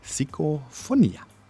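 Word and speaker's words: Psicofonía: Er heißt Psicofonia oder auf Spanisch eigentlich mit der Betonung Psicofonia.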